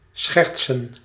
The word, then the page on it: joke: English